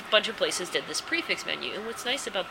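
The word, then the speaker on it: prix fixe: It's like P-R-I-X, which in French is pronounced pre instead of pricks. And then it's like F-I-X-E, which is just fix. – So you want to say pricks fix, but it's prefix.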